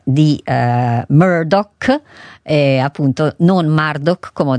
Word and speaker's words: Murdoch: Comunque Murdoch, così si pronuncia, e quindi spero di ricordarmelo per le prossime volte, perciò grazie a chi me l'ha segnalato.